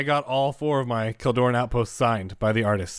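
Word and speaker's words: Kjeldoran: Yes, now that we have the official pronunciation. – Yeah, it's definitely Keldoran, right? – Yeah, Keldoran Outpost. Yeah.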